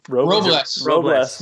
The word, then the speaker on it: Robles: Took Victor Robles. Am I saying that right? – Robles.